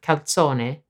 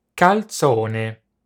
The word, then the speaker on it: calzone: The authentic Italian pronunciation of calzone differs from the English in two main ways. First, the Z is pronounced ts. – Second, the word final e is always pronounced. Have a listen to the correct pronunciation.